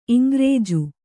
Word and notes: ಇಂಗ್ರೇಜು: Kannada; iŋgrēju

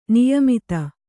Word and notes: ನಿಯಮಿತ: niyamita

